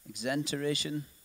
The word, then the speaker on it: exenteration: Is that how you say it, Lindsay? Exenteration surgery? Sure. Exenteration surgery, which basically means everything in this area taken out.